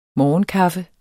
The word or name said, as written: morgenkaffe